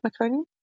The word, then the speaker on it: meconium: Did I say that right, meconium? – Yep.